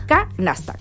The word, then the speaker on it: Knasta: Canasta. K-N-A-S-T-A. Yeah. Y Scart, la otra. S-C-A-R-T.